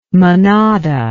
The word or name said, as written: manada